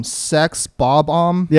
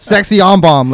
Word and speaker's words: Sex Bob-Omb: See if I can pronounce it. Okay. The band from Scott Pilgrim, um, Sex Bob-Omb. Yep. There you go but there we go. – That's it. I was like, sexy on bomb.